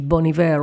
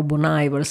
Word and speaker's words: Bon Iver: Allora vi lascio alla voce di Bon Iver o Bon Iver, se preferite pronunciarlo all'inglese.